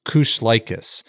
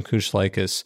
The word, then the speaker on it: Kushlikis: I said it like 10 times, Kushlikis. Mm-hmm. It doesn't look like it is spelled. It sounds much different than it's spelled, but it's Todd and Allison Kushlikis.